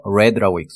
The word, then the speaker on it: read: 과거형이라서 read라고 읽습니다.